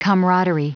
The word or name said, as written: camaraderie